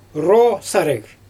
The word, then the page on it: roo saryG: Xyzyl